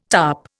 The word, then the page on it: tarp: Indian